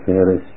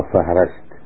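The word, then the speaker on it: فِهْرِس: وعلى كلمة فهرس، الكلمة تلفظ هكذا، فهرس وليس فهرس أو فهرست، فهرست وفهرس.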